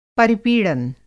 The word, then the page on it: परिपीड़न: Hindi